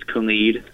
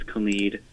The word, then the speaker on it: Khalid: He said because there's the three artists, and first of all, it's Khalid. That's how it's pronounced. It's Khalid with the I. That's Khalid.